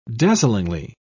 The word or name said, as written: dazzlingly